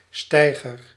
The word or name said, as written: small